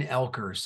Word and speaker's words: Oelkers: It's Blaine Oelkers, so it's almost Elkers, like the O drop the O.